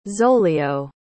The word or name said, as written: zolio